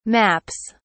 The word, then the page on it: maps: British English